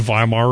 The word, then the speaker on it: Weimar: All of these things were present in the Weimar Republic 100 years ago. Weimar, spelled W-E-I-M-E-R. In German, the W is pronounced with a V.